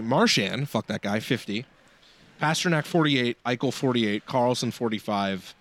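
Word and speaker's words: Pastrňák: So Pastrnak has 28 goals. Yes, I said that correctly.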